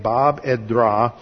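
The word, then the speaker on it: Bab edh-Dhra: That's spelled B-A-B. E-D-H hyphen, capital D-H-R-A, Bab Edra.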